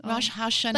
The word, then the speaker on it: Rosh Hashanah: Rosh Hashanah.